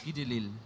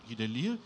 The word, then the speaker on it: Guy Delisle: Wir finden, wenn ich oben links anfange, vieles von Taniguchi, dann Guy Delisle, spricht man den so? Der also die Guy Delisle, ja. Guy Delisle.